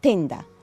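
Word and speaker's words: tenda: وفي تندا، تندا يعني بالطلياني ستارة أو مظلة، بس بقى هم بيكسروا وبيقولوا إيه؟ تندا.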